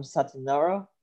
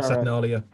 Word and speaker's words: Saturnalia: Forgive me if I say it wrong, Saturnara. – Saturnalia.